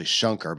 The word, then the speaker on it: Shankar: It's actually Shankar, but S-H-A-N-K-A-R. But you'll hear it pronounced Shankar, Maya Shankar.